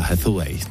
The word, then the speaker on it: Hathaway: Teď ale něco pro vás, kdo jste fanoušci americké herečky, nádherné americké herečky Anne Hathaway, Hathaway, takhle ji vyslovují, a filmu Deník princezny.